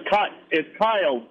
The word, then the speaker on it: Kyle: Let's go to, I think it's Allie in Wisconsin, line four. – It's Kyle. Brandon, it's Kyle. – Oh, well, they don't have your name right. Sorry about that. It's spelled A-Y-L-E on the screen, so sorry.